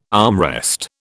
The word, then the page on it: armrest: British English